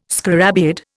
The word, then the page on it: scarabaeid: Indian